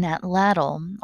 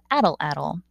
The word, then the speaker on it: atlatl: It's a specific tool that we call today an atlatl, although some people pronounce it addle-addle. Either is correct.